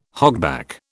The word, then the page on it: hogback: British English